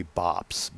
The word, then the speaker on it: Bops: That would be Bops, B-O-P-S.